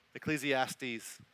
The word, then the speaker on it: Ecclesiastes: Genesis chapter 1 and the book of Ecclesiastes. Did I say that wrong? Ecclesiastes. That's right.